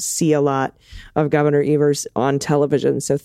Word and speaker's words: Evers: I want to correct your pronunciation of our governor's name, Troy Evers. The E says its name. Troy Evers, I guess? – It is Evers.